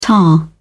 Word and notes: ta: British English

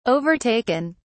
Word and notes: overtaken: British English

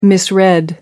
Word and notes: misread: American English

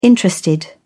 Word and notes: interested: British English

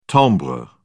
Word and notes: timbre: British English

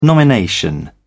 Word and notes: nomination: British English

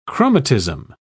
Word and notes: chromatism: British English

